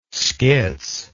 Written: schiz

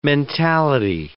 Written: mentality

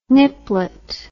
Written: niplet

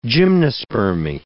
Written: gymnospermy

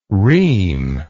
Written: rheme

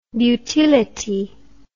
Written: beautility

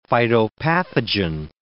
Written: phytopathogen

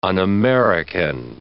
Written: un-American

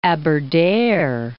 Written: Aberdare

